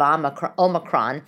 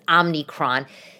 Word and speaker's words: Omicron: Omicron. Um, which I'm finally pronouncing correct because for some reason I was thinking it was Omnicron.